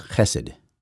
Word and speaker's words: Chesed: It's pronounced Chesed or Chesed. That's the way I pronounce it anyway.